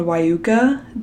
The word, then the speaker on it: Wieuca: And then there's this band called Wyuca, W-I-E-U-C-A.